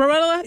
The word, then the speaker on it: Barilla: So Barilla, I think that's how you say it, that pasta. – That's how I would say it. – Barilla.